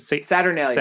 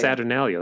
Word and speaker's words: Saturnalia: Every seven years, Satalia. – What's how you say it? – Saturnalia. – Saturnalia.